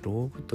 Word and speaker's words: LOG: LOG と書いてローグ